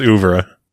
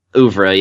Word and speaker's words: oeuvre: pretty sure it's Uvra. – Uvra, yeah.